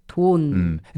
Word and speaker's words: 돈: 돈. – It's a short, one-syllable word. It's pretty easy to pronounce, 돈.